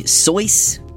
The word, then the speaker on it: Seuss: And either as a fun fact or to preempt any corrections I get about this, yes, the proper pronunciation of his pen name is apparently Seuss, Dr. Seuss, but seeing as most people in the US at least say Seuss, much like we say Van Gogh, despite that being a completely incorrect pronunciation, I thought I'd just stick with that for clarity's sake.